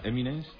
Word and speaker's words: Eminem: Eminem.